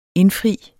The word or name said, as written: indfri